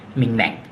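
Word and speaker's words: Minh Mạng: มิงแหม่งอ่านว่ามิงแหม่ง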